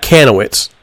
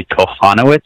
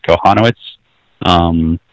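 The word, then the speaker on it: Kochanowicz: I was talking to one of the guys with USA Baseball last summer, and he told me the pronunciation was actually Kochanowitz. – Kochanowitz. – So the CH is silent, and that's how I've been saying it.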